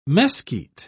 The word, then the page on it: mesquit: British English